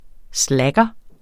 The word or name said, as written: slacker